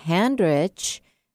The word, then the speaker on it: Handrich: If we are already pals on Facebook, you can go to my personal page, Debbie Handrich, and that's just like it sounds, H-A-N-D-R-I-C-H.